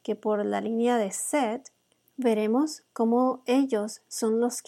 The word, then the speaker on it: Seth: Seth en hebreo se pronuncia Sheith su nombre en inglés significa compensation, o sea, compensación, reemplazo o sustituto.